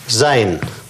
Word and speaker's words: sein: Вот, например, сочетание этих гласных – и ЭИ. Читается по-немецки как «ай». Например, «зайн».